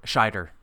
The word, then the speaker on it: Scheider: Scheider.